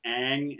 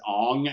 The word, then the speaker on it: Aang: actually say them in a cartoon and pronounce it Aang and whatnot, and then to go into the show and pronounce it Ong and just completely fuck it up,